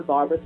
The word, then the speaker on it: Barbra: Barbara spelled like Streisand, B-A-R-B-R-A, Gilman with one L.